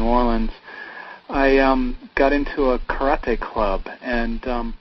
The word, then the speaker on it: karate: Yes, I speak Japanese, so karate is the correct pronunciation.